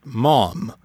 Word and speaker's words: Maugham: Somerset Maugham, which rhymes with how an English person might say warm or form. The GH in the name is silent.